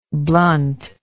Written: bluntness

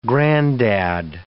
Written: granddad

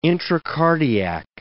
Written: intracardiac

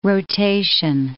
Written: rotation

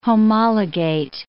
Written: homologate

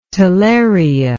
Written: talaria

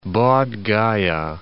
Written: Bodh Gaya

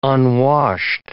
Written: unwashed

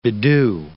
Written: bedew

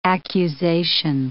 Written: accusation

